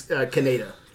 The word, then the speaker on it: Kaneda: I actually listened to the English as well, so I could get his name right. It's Kaneda.